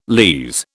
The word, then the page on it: lees: British English